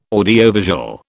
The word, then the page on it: audiovisual: British English